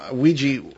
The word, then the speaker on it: Ouija: I've always thought the name Ouija or Ouija, as you pronounce it, you pronounce it the way it looks, we, French for yes, ya, German for yes.